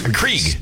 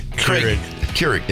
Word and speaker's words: Keurig: Johnny brought in a coffee maker because Krieg. – Keurig.